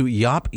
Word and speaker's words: Jaap: Yeah, so this goes to Jaap Jacobs, and I hope I'm pronouncing his name correctly, J-A-A-P, Jaap.